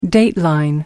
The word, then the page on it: dateline: American English